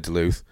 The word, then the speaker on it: Duluth: Is it Duluth or Duluth? But it's probably Duluth. – When you say it probably is, it is. – Oh, well, how do you know? – Because that's how you pronounce it.